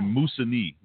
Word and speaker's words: Moosonee: But the name of this place is actually Moosonee, not Moosonee or Missoni or Moosonee. That's exactly how you say it.